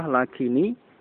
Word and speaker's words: lakini: Sukun yang pertama diganti dengan kasrah. Tujuannya untuk menghindari bertemunya dua huruf yang sukun. Ya, sehingga diucapkan lakinitullabu, lakinitullabu, lakinitdzalimuna. Nah, diucapkan dengan kasrah lakini, lakinitullab, lakinitdzalimin, itu untuk menghindari bertemunya dua huruf yang sukun. Ya. Adapun aslinya lakin itu dengan sukun.